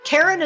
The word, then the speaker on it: Karen: Karen is spelled the normal way, no goofy Y's or Q's, K-A-R-E-N, and Cataline is spelled K-A-T-A-L-I-N-E.